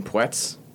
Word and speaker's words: Puetz: Tennis players, Ben Schmude and Tommy Puetz, which we're just finding out that's how you pronounce his last name, despite it being spelled P-U-E-T-Z, because we thought Tommy Puetz, which would have been...